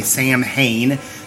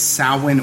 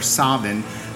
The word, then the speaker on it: Samhain: You English speakers will say Samhain, mispronouncing it, but it's Samhain or Samhain, depending on if you're Irish or Scottish.